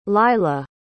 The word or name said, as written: lylah